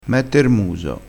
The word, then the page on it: mette r muso: dialettale